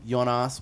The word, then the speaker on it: Yonnas: But like, um, the single is on Spotify and all that shit right now under my name, Yonnas Y O N N A S Macaroni.